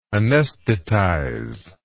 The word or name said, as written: anaesthetization